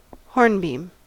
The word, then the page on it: hornbeam: American English